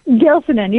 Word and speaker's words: Gilsinan: Gilsonen, you got it.